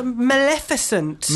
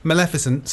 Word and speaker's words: Maleficent: It's M-A-L-E-F-I-C. There's no I there. – And in the film, they call her Maleficent. – I'll get her. – Anyway, Maleficent, Mistress of Evil, sequel to Maleficent, surprise, surprise.